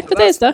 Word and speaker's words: Bethesda: I'm going with Bethesda. – You got it though, I got it that time. – I don't know if that's how we pronounce it. – Yeah, Bethesda. – That's what I'm fucking going with, so. – I'm sure that's how you pronounce it.